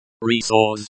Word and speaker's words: ресурс: Pronounced resource, emphasis on sore.